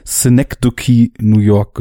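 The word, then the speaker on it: Synecdoche, New York: ich habe in US-Podcasts mal Synecdoche New York gehört.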